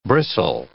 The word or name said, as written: bristle